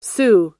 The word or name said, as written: xu